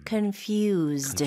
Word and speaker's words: confused: Confused. – Confused. – 중간에 S가 있는데요. Z 발음으로 해주시면 좋아요. – Okay. Confused. Z-E-D처럼요.